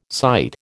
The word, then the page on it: cyte: American English